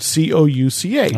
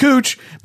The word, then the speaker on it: Couch: C O U C H. I knew you were going to say Cooch. It, it, pronounced Cooch because it's named after a person, but everyone else would say Couch.